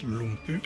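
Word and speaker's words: Lumbye: Ça s'écrit Lumbay, il paraît que ça se prononce Lumpy.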